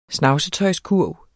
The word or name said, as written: snavsetøjskurv